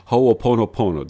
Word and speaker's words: Ho'oponopono: I believe it's Ho'oponopono. Did I say it correctly? – Pretty good. Pretty good. It's ho'o, which means to make, ponopono, good, to make right, right.